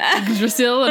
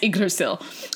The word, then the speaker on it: Yggdrasil: You know how I know how to pronounce that? – How? – I watched an episode of QI with Stephen Fry. – Great. – And he, like, makes a point of saying, like, Yggdrasil, and I'm like, that's how you say it. – Thank you, Stephen Fry. – I don't need to know anything else other than that's how you pronounce Yggdrasil.